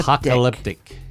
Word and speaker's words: apocalyptic: And now I can't say it right. Apocalyptic. Okay. – Apocalyptic. Yeah, it's a tough word.